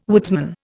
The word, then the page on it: woodsman: Indian